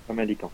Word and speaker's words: Famalicão: Euh, deux ans donc à, chez nos amis du Sporting Portugal et qui est arrivé en provenance de Famalicao, si je ne me trompe pas, et je dois prononcer assez mal, non, c'est ça Famalicao, c'est ça.